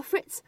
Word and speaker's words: fritz: 这个表达呢非常非常的口语，这个 fritz 是 f r i t z，fritz 末尾呢是一个 ts 的发音。